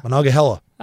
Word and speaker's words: Monongahela: I don't know how to pronounce it. I'm going to do my best. – Oh, Christ. – Monongahela? – Monongahela. – That was okay.